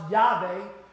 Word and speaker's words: Yahweh: The sacred name of God is Yahweh, pronounced Yahweh. There is no W in the Hebrew letter, so it's replaced with the V sound, Yahweh.